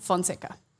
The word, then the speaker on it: Fonseca: CN Fonseca.